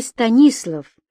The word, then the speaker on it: Станислав: «Послушай, Станислав!» Алексей делал специальное ударение в его имени на второй слог, подчеркивая тем самым его якобы польское благородное происхождение.